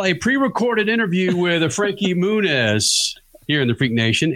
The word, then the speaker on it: Muniz: Well, the official way to pronounce it is Muniz. Muniz. Yeah. Muniz.